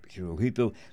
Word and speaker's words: Hirohito: By the way, the Emperor of Japan, his last name is spelled H-I-R-O-H-I-T-O. Most people pronounce it Hirohito. Especially the correct pronunciation is Hirohito.